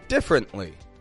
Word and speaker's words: differently: We're going to look at a handful of words with reduced vowels, where the vowel is written, but it's not actually pronounced. Let's consider a word that I've already said, uh, differently.